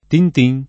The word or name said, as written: tin tin